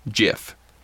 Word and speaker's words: GIF: You know, fun fact is the guy who created those actually came out and settled the whole argument and said it is pronounced GIF. – Like the peanut butter? – Yes.